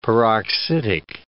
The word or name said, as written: peroxidic